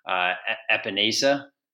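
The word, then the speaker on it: Epenesa: They held up well against Epinesa. Is that how you say it? – Sure. Yeah, that's how we say it.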